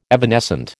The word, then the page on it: evanescent: American English